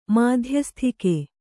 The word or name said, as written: ಮಧ್ಯಸ್ಥಿಕೆ